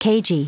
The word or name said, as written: cagey